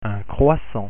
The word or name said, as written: croissant